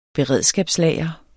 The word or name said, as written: beredskabslager